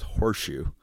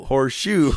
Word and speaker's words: Horshu: And his name was Horseshoe. – Really? Horseshoe? That's awesome. – But it was spelled like H-O-R-S-H-U. – Horseshit? – No. H-O-R-S-H-U. – U. – Horseshoe.